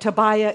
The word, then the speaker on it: Tobiah: So the enemies, you're wondering how to pronounce those names, right? Sanballat, Tobiah, Geshem. Those are how to pronounce the three names.